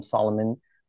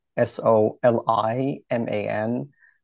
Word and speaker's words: Soliman: So with my website dr like doctor and then last name Soliman S O L I M A N. Um, you can blame my parents for writing it this way. Uh, they butchered the Solomon name. And uh, all the links for my social media are on the website. And all the social media have the same handle, Dr. Soliman, S O L I M A N, and then MD, like medical doctor, one word.